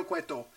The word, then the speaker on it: Cueto: They hired this actor, Dario Cuarto. Thank you. Dario Cuarto. And I'm probably saying it wrong now. Dario Cueto. And he's amazing.